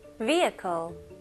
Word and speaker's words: vehicle: 여기 중간에 있는 H는 그냥 묵음이라고 생각하시면 좋아요. 그래서 V, 그 다음에 바로 I로 가시면 돼요. Vehicle.